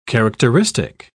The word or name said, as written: characteristic